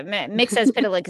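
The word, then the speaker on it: Mxyzptlk: Mixis Pitlick is how they say it on the show. He has very differing interpretations of the name, but Mixis Pitlick, yes.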